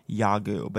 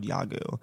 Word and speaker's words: Yawgoog: Yagu, but spelled Y-A-W-G-O-O-G. And no one knows why it was spelled that way. – Okay. – It's spelled Yaogug, but it was called Yagu.